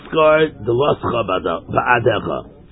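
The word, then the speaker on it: scarred the loscha ba'adecha (סגר דלתיך בעדך): It's scarred the loscha ba'adecha. That's the pronunciation.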